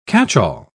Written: catchall

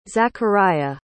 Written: zacharia

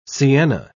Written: sienna

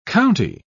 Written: county

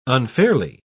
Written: unfairly

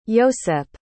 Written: iosep

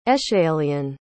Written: aeschylean

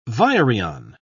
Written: virion

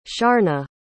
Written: sharna

